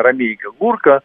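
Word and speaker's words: Ромейко-Гурко: Это были военные агенты, люди случайные, как вот если взять, в пример, Владимира Ромейка Гурка, я правильно произношу его фамилию, именно Ромейка Гурка, он, пережидая назначение на полк, дал согласие где-то там год с небольшим поработать военным агентом, уехал в Швейцарию.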